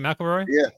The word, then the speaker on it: McElroy: McElroy. Is it McElroy? – Yeah. – God damn it! – It's just like a Big Mac, like a gun or right.